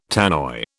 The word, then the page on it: Tannoy: British English